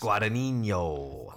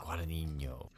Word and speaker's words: Guadagnino: Directed by Luca, how do you say his last Guadagnino. Guadagnino.